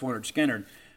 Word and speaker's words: Lynyrd Skynyrd: I think in many respects kind of overshadowed because of all the hits that came on Pronounced Leonard Skinner, but a really strong sophomore effort from Lynyrd Skynyrd.